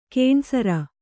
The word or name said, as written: ಘನಸಾರ